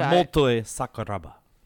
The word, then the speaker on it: Motoi Sakuraba: Matoy Sakuraba. – Okay. You pronounced it. Right. You pronounced it as well as you could. – Motoi Sakuraba.